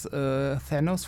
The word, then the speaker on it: Thanos: Also, wobei ich habe tatsächlich mal gehört, also optisch ist natürlich Dark, ist natürlich Thanos oder Thanos oder wie man auch immer ihn aussprechen möchte. – Thanos ist cool, wollte ich nur kurz sagen. Thanos ist richtig, Thanos ist super cool, aber Darkseid war zuerst.